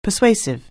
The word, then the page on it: persuasive: anglais